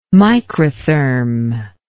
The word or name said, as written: microtherm